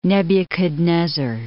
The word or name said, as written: Nebuchadnezzar